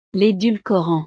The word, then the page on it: l'edulcorant: frances